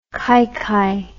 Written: kaikai

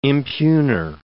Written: impugner

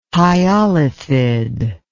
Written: hyolithid

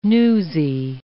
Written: newsy

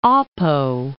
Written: Apo, Mount

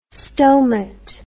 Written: stomat

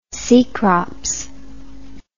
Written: cecrops

